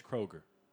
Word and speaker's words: Kroger: It's pronounced Kroger. – What'd I say? – Kroger's. – Ain't no apostrophe S? – There's no S at all.